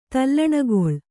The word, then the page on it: ತಲ್ಲಣಗೊಳ್: tallaṇagoḷ